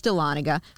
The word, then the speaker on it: Dahlonega: And then in parentheses, it's Dahlonega.